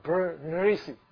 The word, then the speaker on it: Berenice: So it's pronounced as Bernice. B-E-R-E-N-I-C-E. Bernice. Bernice. Or Bernice. But you know, Eunice is Eunice.